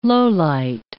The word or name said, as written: lowlight